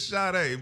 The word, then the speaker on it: Sade: Did you say Sade? – What is it? – It's Sade, but you. – Sade? – Yeah, it's spelled Sade.